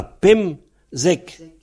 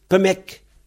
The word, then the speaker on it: pemzek: 15, on a Pemzek. Mais en fait, beaucoup de gens, surtout en S20, ils prononcent Pemek. Au lieu de Pemzek, ils disent Pemek.